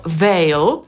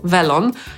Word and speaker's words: they'll: A kiedy łączymy they z will, to słowniki podają, że powinniśmy to wymawiać jako veil, czyli tak jak veil, velon, ale przez w. Ale powiem szczerze, że nigdy tego nie słyszałam w tej wersji. Najczęściej wszyscy mówią vel.